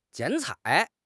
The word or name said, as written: 剪彩